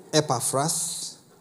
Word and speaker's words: Epaphras: Epaphras.